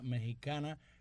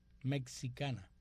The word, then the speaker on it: mexicana: Ahora, en tus comentarios sobre la cantante mexicana, pronunciaste la X de la palabra mexicana con los sonidos KS, o sea, dijiste mexicana. La pronunciación de este gentilicio es mexicana. La X se pronuncia como J en México, mexicana en español.